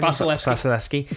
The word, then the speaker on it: Vasilevskiy: Andre Valesky, is that how you say his name? Vasilevskiy.